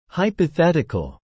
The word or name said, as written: hypothetical